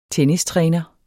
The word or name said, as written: tennistræner